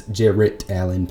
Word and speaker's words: Jarrett Allen: They also got rid of Jared Allen, I, and I don't think I pronounced the T on that name hard enough. It's Jarrett Allen, pardon me, and a solid center, the Fro.